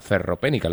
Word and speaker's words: ferropénica: Cuéntanos un poquito acerca de la ferropénica. Lo he dicho bien, ¿no? – Sí, está perfecto.